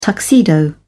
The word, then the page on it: tuxedo: British English